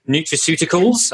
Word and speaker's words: nutraceuticals: Dr. James is a seasoned and successful entrepreneur who has founded companies in media, consumer packaged goods, nutraceuticals. Am I saying that right, James? – It's a great Scrabble word, James, and you crushed it.